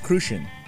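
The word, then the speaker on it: Cruzan: It's pronounced Crucian. – It's not Crouzon? – Not Crouzon.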